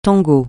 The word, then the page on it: tango: francais